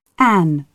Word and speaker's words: Anne: Anne, with an E. So that's A-N-N-E.